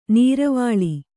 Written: ನೀರವಾಳಿ

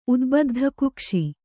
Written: ಉದ್ಬದ್ಧಕುಕ್ಷಿ

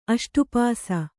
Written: ಅಷ್ಟುಪಾಸಿ

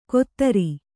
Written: ಕೊತ್ತರಿ

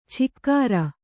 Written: ಛಿಕ್ಕಾರ

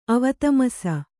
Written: ಅವತಮಸ

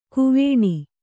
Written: ಕ್ವಣ

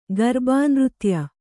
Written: ಗರ್ಬಾನೃತ್ಯ

